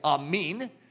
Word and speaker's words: amen: It's pronounced a little differently, amin, which we're always like, that's not correct. Well, they're the ones that came up with it. Technically, that's correct, but it's okay in America to say it our way.